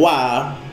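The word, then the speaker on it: wa (は): Partikel wa di sini secara penulisan itu dari h. Oke ya. Bukan wa hiragana tapi h hiragana. Cuman penulisan partikel wa ini penulisannya emang menggunakan h, tapi cara bacanya wa.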